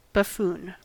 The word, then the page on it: buffoon: American English